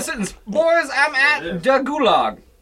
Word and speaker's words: gulag: I thought gulags was German anyway. – Not Russian? – Yeah, how do you spell that? – G-U-L-A-G. Gulag. Kind of like goulash.